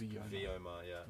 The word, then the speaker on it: Vyoma: I do believe it's pronounced Vyoma. Vyoma, which is a Sanskrit word for in the higher realms of the universe.